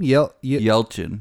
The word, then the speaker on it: Yelchin: Yelchin.